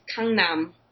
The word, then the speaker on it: Gangnam: Have you ever heard of Gangnam style, Gangnam style, however you Americans say it. – How how is it supposed to be said? – In Amer- in Korea, it's Gangnam.